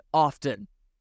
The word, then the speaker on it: often: The only thing that is more irritating, Dave concludes, is pronouncing the T in often.